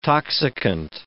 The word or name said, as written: toxicant